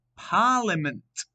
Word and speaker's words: parliament: Number 18, the word is parliament. Parliament. Now, you have to be careful here because there's a silent letter in it.